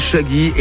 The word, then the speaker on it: Shagi: Rien à voir hein, avec l'artiste américano-jamaïcain auquel on doit entre autres le fameux tube « Oh Carolina ». Notre Shaggy est toulousaine et son nom s'écrit S-H-A-G-I.